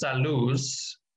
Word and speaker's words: Saluz: So the company name is Saluz, and in Mexico, I'm sorry, in Spanish, Salud is health, and Luz is light.